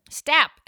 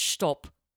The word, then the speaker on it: Stapp: Wait, is that how you pronounce his last name? – Stapp, S-T-A-P-P. Can you imagine if this whole time he was like, you guys, it's stop.